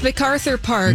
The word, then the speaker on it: MacArthur Park: Now, earlier I was pronouncing MacArthur Park wrong, apparently. So I just want to clarify about that. Is it MacArthur Park? – It's MacArthur Park.